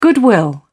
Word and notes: goodwill: British English

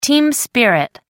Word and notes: team spirit: British English

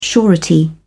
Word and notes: surety: British English

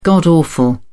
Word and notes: god-awful: British English

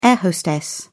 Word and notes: air-hostess: British English

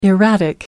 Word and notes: erratic: American English

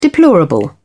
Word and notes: deplorable: British English

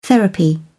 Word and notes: therapy: British English

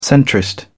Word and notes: centrist: British English

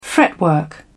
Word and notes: fretwork: British English

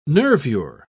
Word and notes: nervure: British English

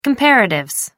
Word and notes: comparatives: American English

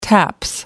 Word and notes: taps: British English

